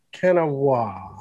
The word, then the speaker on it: Kanawha: we said the Kanawha River, and Kanawha, right? – Kanawha. – Kanawha.